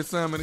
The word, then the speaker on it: salmon: Say salmon again. – Salmon. – Coming up in 34 minutes after the hour. – S-A-L-M-O-N. – The L is silent.